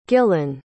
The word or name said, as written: gillan